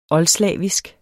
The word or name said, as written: oldslavisk